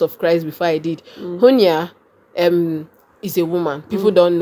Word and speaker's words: Junia: He said, verse 7 of Romans 16, he said, greet Andronicus and Junior, or Junia, as they pronounce it, greet Andronicus and Junia, my fellow Jews who were in prison with me.